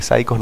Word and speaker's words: Saicos: Un dato a destacar del título de Los Saicos es que Saicos no se escribe como psych. – Como psicópata en inglés, psycho. – Exacto, se escribe en su sentido fonético, S-A-I-C-O-S.